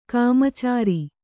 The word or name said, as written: ಕಾಮಚಾರಿ